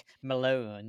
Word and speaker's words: Malone: It's after the scene where Sally does, like, Malone, that's a good yes. – name. And she, like, it rhymes with, like, phone, trombone, bone.